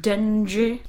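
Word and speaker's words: Dengie: Actually, it's pronounced Dengue.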